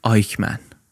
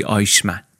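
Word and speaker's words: Eichmann: یکی از اینا یه آقایی بود به نام آدولف آیکمن، یا به تلفظ فارسی آیشمن.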